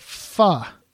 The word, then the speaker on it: pho: It's called pho. – Pho. – Yeah. It's spelled like P-H-O.